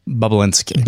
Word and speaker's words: Bobulinski: And forgive me, the correct pronunciation of the whistleblower's name here is? – Bobulinski.